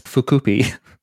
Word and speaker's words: Fukuppy: This is um, created by Fukushima Industries, but so I'm sure it's Fukuppy, but it's spelt fuck uppy.